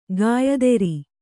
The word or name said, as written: ಗಾಯತ್ರಿ